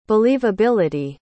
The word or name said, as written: believability